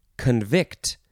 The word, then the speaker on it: convict: Next word is convict, with the emphasis on the second syllable.